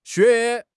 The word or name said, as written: xue3